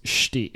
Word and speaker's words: Stig: It's Stig. It's S-T-I-G, but it's pronounced Stig. He directed God of War 3 is basically what I'm saying.